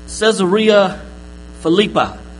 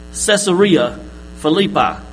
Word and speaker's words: Caesarea Philippi: So, Caesarea Philippi. Say, pizzeria. Alright? Pizzeria. This is Caesarea Philippi.